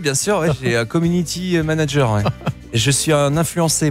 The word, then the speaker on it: community manager: Bah, ils ont ce qu'on appelle un community manager. Hein. Je l'ai mal prononcé. – Community manager.